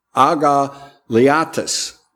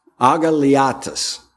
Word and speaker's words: agaliatis: It is pronounced agaliatis, agaliatis. And it's difficult to switch over to English letters, but I'll try.